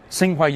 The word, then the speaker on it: Tsinghua: Frank Zhao is from Tsinghua University. Did I pronounce that right? – Yeah, right. Yeah.